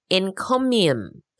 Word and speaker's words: encomium: This is encomium. Oh, I'm not saying that right. Encomium. Encomium. That's E-N-C-O-M-I-U-M. Encomium. It sounds like a weird version of the economy, but it's not.